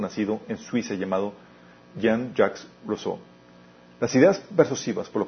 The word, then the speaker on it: Rousseau: Jacques Rousseau, Rousseau ¿cómo se pronuncia? Rousseau, ah, gracias, Rousseau.